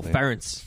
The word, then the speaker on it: Ferentz: Oh, Ferentz.